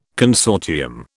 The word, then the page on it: consortium: British English